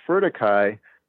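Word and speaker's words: Whero-kōwhai: And he named it Wherokowhai, which is a Maori term pronounced furtikai for red and gold or red and yellow.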